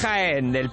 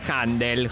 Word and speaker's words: Jandel: Es Händel, pero en realidad nadie lo escribe. Entonces voy a simplificar Händel. J-A-N-D-E-L, mi amor.